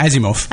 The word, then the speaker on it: Asimov: Well, you could be like Asimov. I can't say his name. – Yeah, Asimov.